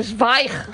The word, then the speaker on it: Zweig: Tú sabes que yo toda mi vida he estado diciendo que yo leía Stephen Zweig. – Ah, claro. Es no. Zweig.